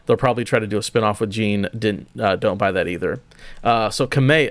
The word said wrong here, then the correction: Kamiya: how do you say his name? I always say it wrong. – It is Hideki Kamiya.